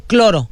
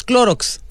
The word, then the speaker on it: Clorox: Cloro. No es cloro, es Clorox.